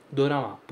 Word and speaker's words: dorama: Drama, é literalmente drama pronunciado como o japonês pronunciaria, dorama. Por isso que tem esse O no meio do caminho, ok?